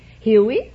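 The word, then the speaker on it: Hewie: No, it's H-E-W-I-E. It's the syllable that I say. Huey. You let your tongue be loose inside and just say Huey.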